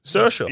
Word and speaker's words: Saoirse: My favorite is Saoirse. – Saoirse's good. I also love Saoirse is like you look at it, you're like, best of luck to me to say it. – Yeah. – Easy. Saoirse.